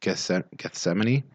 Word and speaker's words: Gethsemane: I mean, you know, we're already talking about kind of that Garden of Gethsemane.